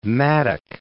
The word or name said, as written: mattock